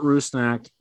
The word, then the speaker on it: Rusnak: For example, the Albert, correct me if I'm pronouncing this wrong, Rusnak signing? – Rusnak.